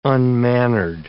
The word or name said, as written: unmannered